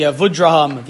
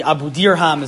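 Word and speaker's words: Abudarham: On page 6, I have the Avudraham. The Abudirham is the correct pronunciation, but an Ashkenazi yeshiva is always called the Avudraham.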